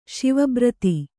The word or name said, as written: ಸಾವಭೃತ